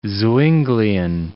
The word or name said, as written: Zwinglian